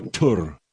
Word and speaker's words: Thor: Now they pronounce Thor Tur. I know that much.